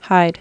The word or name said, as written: hide